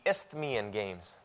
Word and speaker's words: Isthmian: The second most important games was a word that I have problems pronouncing. Isthmian Games. The Isthmian Games. There's an S and a TH right beside each other.